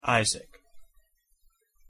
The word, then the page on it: Isaac: English